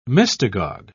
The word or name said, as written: mystagogue